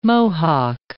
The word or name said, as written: Mohock